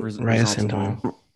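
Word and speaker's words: ressentiment: How is that word pronounced? Is it the reus sentiment? – Resentiment.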